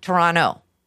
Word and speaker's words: Toronto: So it's like Toronto. You never say that T.